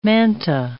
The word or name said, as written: manta